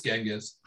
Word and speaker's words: Genghis: Was it A, the great Khan, Genghis, or Genghis, depending on your pronunciation? – Sorry, Dan Carlin, it's Genghis.